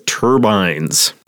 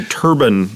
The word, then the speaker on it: turbine: Well, just the pronunciation turban that we heard earlier, I think of that as... You say turbine, right? – Yeah, turban is something you wear on your head.